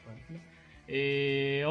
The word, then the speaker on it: Tlalnepantla: Ay, lo dije bien, Tlalnepantla.